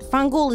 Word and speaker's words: fangul: Fangul is the Italian-American pronunciation of vaffanculo. Right? We cut the last vowels, right? So we say fangul.